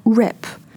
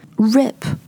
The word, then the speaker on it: rip: So we started with the word wrap, to wrap up something. Wrap, a, W-R-A-P, wrap, 它的元音是 a 的音 a, wrap. And now we're talking about rip, rip, R-I-P, rip, 它的元音是 i, i 和 a, 你可以听得出来它们是不一样的吗？So, rip off and wrap up.